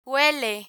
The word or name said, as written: huele